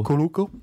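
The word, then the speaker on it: Cuoco: How do you say her name? – Kuoko. – Kuoko.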